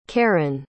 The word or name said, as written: karen